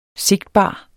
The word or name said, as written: sigtbar